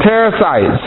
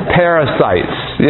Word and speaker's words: Perizzites: It was full of Canaanites, Hittites, Jebusites, Hivites, all those other ites, Ammonites, Amorites, Perizzites. I know I mispronounced that on purpose. Perizzites, yes.